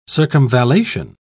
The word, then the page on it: circumvallation: British English